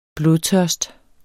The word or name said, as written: blodtørst